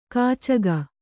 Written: ಕೀಚಕ

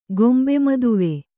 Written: ಗೊಂಬೆಮದುವೆ

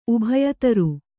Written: ಉಭಯತರು

